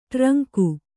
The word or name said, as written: ಟ್ರಂಕು